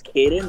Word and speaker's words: Cayden: My name is Cayden.